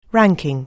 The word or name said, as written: ranking